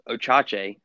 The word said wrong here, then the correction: Ochai: Ochai Agbaji.